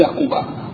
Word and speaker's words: قباء: الرقيب مش بعد أن أخذ الثياب يتقدمه الدليل من الدين لحن السرين من قضية الدين ووصلوا إلى قباء، وقباء تلفظ بالمد وبالقصر قباء لكن المد أفضل وأقوى.